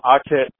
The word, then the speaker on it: Akhet: So, if you go on Facebook, you know, my name is Shakem, S-H-A-K-E-M, Akhet, A-K-H-E-T.